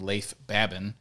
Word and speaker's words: Leif Babin: It's one by Jocko Willink and Leif Babin is actually how you say his name, called The Dichotomy of Leadership.